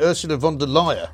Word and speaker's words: Ursula von der Leyen: Is that how you pronounce it? – Well, I pronounce it Ursula von der Leyen.